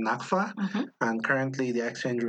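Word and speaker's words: nakfa: It's called the naphtha. – Ding, ding, ding, ding. Wait, say that again. – Naphtha. – Man, I would have pronounced that so wrong.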